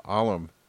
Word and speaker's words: alum: So really, the simplest way to sum this up is the alum. Alum? I think that's how you say it. A-L-U-M. I don't think it's alum, like aluminium.